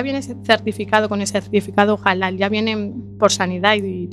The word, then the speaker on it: halal: Halal, carne halal.